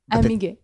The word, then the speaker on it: Amiguet: Amiguet. – T is silent though, right? – The T is silent, but in the US often people...